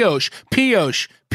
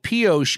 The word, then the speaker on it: Pioche: So Pioche is spelled P I O. C H E. And listener Ben Parks, who sent the submission, even spelled it out phonetically for my dumbass but he wrote P dash O dash sh with like 25 H's.